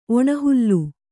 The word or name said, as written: ಒಣಹುಲ್ಲು